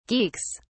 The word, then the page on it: geeks: British English